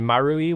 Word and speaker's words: Marui: For women, the major hubs are Shibuya 109 and Marui, which is, it's a play on words. It's spelled 0101, because Maru is circle and E represents the one, so that's what it is.